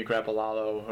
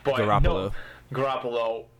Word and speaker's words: Garoppolo: And no one wants to see Jimmy Garoppolo or whatever. I'm sorry for mispronouncing his name; it's wrong. – Garoppolo.